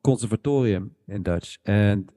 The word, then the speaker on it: Conservatorium: It's also where they have it's so you said the name wrong. It's not the consortium; it's the Conservatorium Hotel.